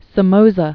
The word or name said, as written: Anastasio Somoza Debayle